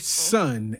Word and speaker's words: Sonn: that's trumpeter Larry Sun and his big band. And Larry spells his name S-O-N-N, but from what I understand, it's pronounced Sun.